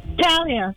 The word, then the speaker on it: Talia: Okay, how do you say your name? – Talia. – Talia. Am I saying it right? I feel like I'm saying it wrong. – No? No, you're saying it right.